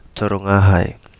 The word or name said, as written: Torongahai